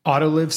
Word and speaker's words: Autoliv: And that company is, I'm not sure how to say it, Autoliv, I think. And the ticker is ALV, and that's Alpha Lima Victor. Thank you much. – Autoliv sounds right to me.